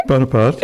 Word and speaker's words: Bonaparte: Napoleon Bonaparte, is that how you say it? – Bonaparte, yes.